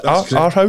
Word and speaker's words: Aarhus: Aarhus? – That's correct.